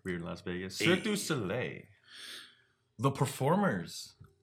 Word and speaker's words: Cirque du Soleil: Cirque du Soleil. I could never say it correctly. – You got it though. – Cirque du Soleil.